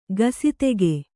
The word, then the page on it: ಗಸಿತೆಗೆ: kannada